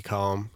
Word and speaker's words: LECOM: So, LACOM – I like to call it LECOM because that's how it's phonetically pronounced.